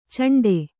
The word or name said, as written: ಚೆಂಡೆ